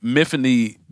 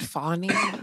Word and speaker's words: Myfanwy: But Miffany, the British version, is spelled horribly. It's like MYFANWY. It's like the W comes after the N. It's Fawny? Weird. It's very weird. – Is it Fawny?